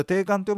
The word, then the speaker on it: 定款: これ定款って読む